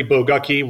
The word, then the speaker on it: Bagooki: It is Larry Bagooki, rhymes with lucky.